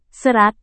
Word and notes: serat: indonesian

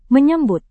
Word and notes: menyambut: Indonesian

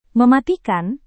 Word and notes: mematikan: Indonesian

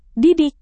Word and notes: didik: indonesian

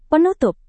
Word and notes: penutup: Indonesian

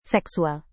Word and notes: seksual: Indonesian

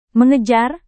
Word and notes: mengejar: Indonesian

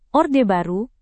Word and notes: Orde Baru: Indonesian

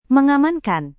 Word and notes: mengamankan: Indonesian

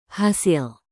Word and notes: hasil: indonesian

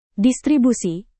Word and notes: distribusi: Indonesian

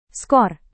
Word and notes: skor: Indonesian

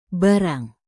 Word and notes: barang: indonesian

